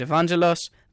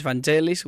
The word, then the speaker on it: Evangelos: And I'm like, but I'm struggling with my name, because my name is Evangelos. The other pronunciation of the name is Vangelis, which is even worse.